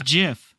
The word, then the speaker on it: Jif: It's Jif. – Oh, no. – We all know that's the case, yeah. Of course. – Yeah. – It's a soft G.